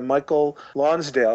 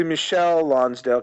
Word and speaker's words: Michael Lonsdale: played by Michael Lonsdale. Although it took me a long time to realize it's actually Michelle Lonsdale, because they did spell it Michael Lonsdale.